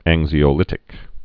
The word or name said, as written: anxiolytics